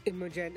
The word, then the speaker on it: Imogen: Imogen and Leonate.